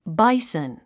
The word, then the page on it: bison: báisn; バイソン